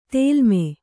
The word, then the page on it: ತೇಲ್ಮೆ: tēlme